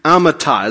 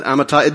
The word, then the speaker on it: Amittai: His dad's name is Amittai, as best I can pronounce that, Amittai.